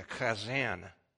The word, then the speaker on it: chazzan: They also had a keeper of the scrolls called a, if I can say this correctly, a chazan. I don't know if that's right or not. It sounds like you're clearing your throat. Chazan. It's spelled C-H-A-Z-Z-A-N.